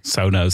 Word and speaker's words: Sonos: Sonos. Zeg ik dat goed zo, Tim? Ja, ja zeker.